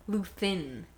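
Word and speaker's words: Luthen: I just realized you said Lutheran. What is his name? Luthin. Luthin.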